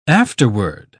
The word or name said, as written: afterward